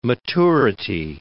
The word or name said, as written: maturity